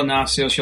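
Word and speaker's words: Nasios: Yeah, it's if you just look up Angelo Nacios, you'll find the channel itself. – Yeah. And Nacios is spelled N like in Nancy. A-S-I-O-S.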